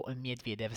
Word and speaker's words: Medvedev: 19.30 sempre Lajovic e Zverev e dalle 21 Ivashka con Medvedev o Medvedev se preferite la pronuncia russa.